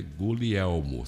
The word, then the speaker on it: Guglielmo: Guglielmo, mas esse é Guglielmo. Você não pronuncia o G em palavras de origem italiana.